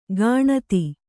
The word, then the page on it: ಕಣತಿ: kaṇati